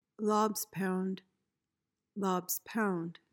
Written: lob's pound

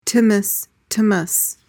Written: tzimmes

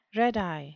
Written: red-eye

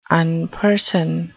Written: unperson

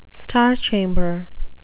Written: star chamber

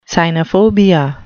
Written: cynophobia

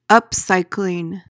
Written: upcycling

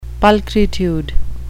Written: pulchritude

